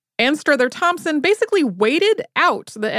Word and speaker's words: Anstruther: It is not 100% clear whether she pronounced this Ainster, Anstruther, or some other variation. She was from Scotland, and there is a town in Scotland where locals say Ainster, but everyone else does not.